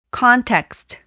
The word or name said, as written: context